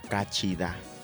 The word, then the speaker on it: cachida: Ok, para saludar en lengua yocotán, nosotros vamos a pronunciar cachida. Para decir hola. – Hola, cachida. – Cachida.